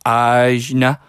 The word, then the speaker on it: Ajna: The third eye chakra is, now I might be pronouncing this wrong, it's A-J-N-A, so Ajna, perhaps. Someone will correct me on that, I suppose. – Well, with that one, it does have an accent over it, so it's actually Ajna.